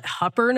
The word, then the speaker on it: Hooper: And sure enough, in, they call it Hupper, Nebraska, but it's spelled Hooper.